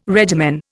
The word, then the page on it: regimen: Indian